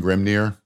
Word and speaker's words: Grimnyr: And if I'm mispronouncing Grimnir, sorry, I'm just G-R-I-M-N-Y-R. – You were spot on, my friend.